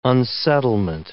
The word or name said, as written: unsettlement